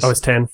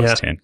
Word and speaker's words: OS X: So just given that we were all decided it's polite to correct people's pronunciations, so you don't do it for years. – OS 10. – Yeah, so OS 10.